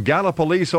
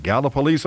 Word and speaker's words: Gallipolis: 990 WJEH in Gallipolis, Ohio. And yes, that's how it's pronounced. If you don't live in Ohio, you probably don't know that. Gallipolis, Ohio.